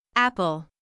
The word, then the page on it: apple: ˈæpəl